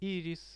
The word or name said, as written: iiris